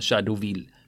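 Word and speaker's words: Jadotville: Och då sa han Shadowville, det vill säga det franska uttalet då.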